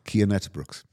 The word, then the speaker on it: Uijtdebroeks: Can you tell me how that's actually pronounced? – Cian Uijtdebroeks.